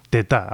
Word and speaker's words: Teta: Su nombre se escribe Teta, igual que esa palabra tan habitual en castellano, pero bueno, al cantante le hemos oído jalearle diciendo Teta, así que bueno, ponemos el acento en la última, Teta, a partir de ahora se llama este intérprete, este guitarrista realmente excelente que viene desde Madagascar.